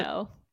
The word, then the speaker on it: Toronto: Toronto. No second T.